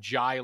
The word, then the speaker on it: Njie: I did confirm that it is pronounced Jai like I, even though it's spelled N-J-I-E.